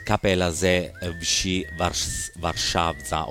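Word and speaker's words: Kapela ze Wsi Warszawa: nella loro lingua natia così dovrebbe pronunciarsi Kapelaze Vsi Warszawa o Warszawa. Praticamente l'ho detto allo stesso modo.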